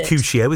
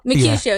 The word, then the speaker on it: Mercutio: Mercutio, isn't it? I'm trying to remember my A-level English. Is it? – Have I pronounced? Mercutio is perfect, yes.